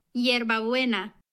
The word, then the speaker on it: Yerba Buena: It is properly pronounced Yerba Buena.